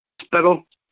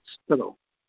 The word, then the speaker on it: Spikl: Spittle. S-P-I-K-L. Spittle. Yes. Sinister Spittle. – Like Skittle? – Spittle. S-P-I-T-A-L.